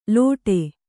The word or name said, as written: ಲೋಟೆ